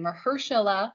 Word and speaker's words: Mahershala: I do know how to pronounce that name, Mahershala, in Marvel's new Blade pick.